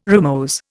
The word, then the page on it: rimose: Indian